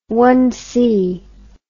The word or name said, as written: one-base hit